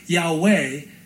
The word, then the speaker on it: Yahweh: We pronounce it, or we could pronounce it, Yahweh. And if you kind of, you know, anglicize it or Yiddishize it, then it could be Yahovah, Jehovah.